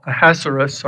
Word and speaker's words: Ahasuerus: Sometime later, King Xerxes, you'll also see him called Ahasuerus, I believe is the correct pronunciation.